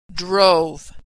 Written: drove

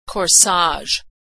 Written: corsage

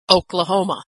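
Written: Oklahoma

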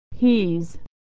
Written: he's

